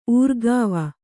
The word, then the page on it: ಊರ್ಗಾವ: ūrgāva; Kannada